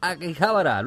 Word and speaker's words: Akihabara: Nos vamos a viñetas desde Akihabara.